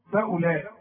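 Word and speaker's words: فأولئك: خطيئته فاولئك فاولئك كلها مرققه، لكن خد بالك من الواو اللي عليها الخمسه اللي العلماء بيسموه حرف عله ما تنطقوش. شوف فاولئك، شوف تلغي من النطق الواو، تكتب ولا تقرا.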